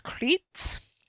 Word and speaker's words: Crait: And it's spelt C-R-A-I-T, so like that rather than like the Greek island.